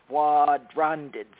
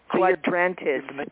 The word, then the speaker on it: Quadrantids: There is a meteor shower called the Quadrantids. That's hard to say. Quadrantids. Quadrantids. It's spelled Q-U-A-D-R-A-N-T-I-D-S.